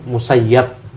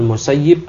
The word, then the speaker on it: Musayyib: Ya, kata Ani bin Musayyib, ada juga yang menjelaskan dobat pembacanya itu Musayyab. Ya, Ibn Musayyib atau Ibn Musayyab.